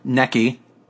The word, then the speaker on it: Nikki: Or Necky. As we know her as. That's how she pronounces it herself.